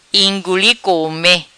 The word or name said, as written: Inguli Come